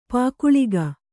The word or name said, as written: ಪೊಗಳಿಕೆ